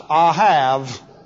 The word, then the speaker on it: ahav: I can always remember it from my first vocabulary, because the Hebrew word for love looks like this, and is pronounced ahav. And so I always, A-H-A-V, ahav or ahav, and I always remembered it by love, I have.